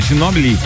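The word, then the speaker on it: Ginóbili: Manu Ginóbio é indicado para o Hall da Fama na NBA. É essa pronúncia mesmo? Isso. É, Ginobili.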